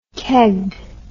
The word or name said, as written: kegged